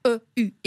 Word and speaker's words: eu: Et donc, quand vous écrivez ces conjugaisons du verbe « avoir » qui s'écrivent « e »,« u » et qui se prononcent « u », comme dans « j'ai eu faim »,